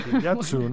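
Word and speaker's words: Kitakyushu: Está bien pronunciado, ¿eh? Kitayutsu, ¿no? – Sí.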